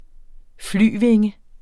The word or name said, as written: flyvinge